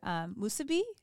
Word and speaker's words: musubi: like your musubi, is that right?